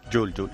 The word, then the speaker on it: Juul: Yule. – Se escribe Juul, pero sí, se dice pero Yule. Pero se dice Yule.